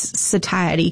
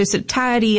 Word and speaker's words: satiety: Like, you're just destroying all the snacks, and you're outpacing your body's satiety cues, right? So satiety is the word that. – Oh, I love the way you pronounce that too.